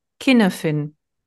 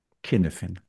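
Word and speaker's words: Cynefin: Wir ordnen jetzt also einmal ein im Walisischen, also da kommt's her. Und von all dem, was ich jetzt so zusammengesucht habe, war die Aussprache, die ich am häufigsten vorfand, Kinefin. – Kinefin. Also ab jetzt Kinefin.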